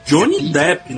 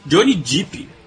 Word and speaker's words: Johnny Depp: É, Johnny Depp, né? Porque tem gente que fala Johnny Deep.